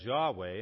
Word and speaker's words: Yahweh: Because it was written without the vowels, there's debate regarding this exact pronunciation. So sometimes you'll hear it as Jehovah and sometimes as Yahweh. And the fact is, we don't know exactly how they pronounced it.